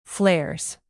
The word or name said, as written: flares